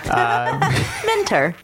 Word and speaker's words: Minter: Written and directed by Emiliano Roca Minter. That's funny that I pronounce his middle name Spanish and his last name like he's Anglo. – Mentor.